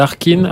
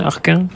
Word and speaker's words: Harkin: Comment tu prononces déjà le nom Fergal Arkin Arkin Arkin, je crois quand même.